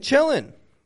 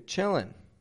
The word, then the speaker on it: chilling: You're chillin'. Notice, I'm not pronouncing the NG on the end of chillin' like I taught NG before. Chilling, ing, ing, in, ing. Okay, Americans do this because we're lazy.